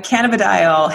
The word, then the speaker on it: cannabidiol: What is this cannibal dolls? Cannibal doll. I'm not saying that right. CBD or you say it. – CBD. Yeah. Cannabidiol, hemp oil.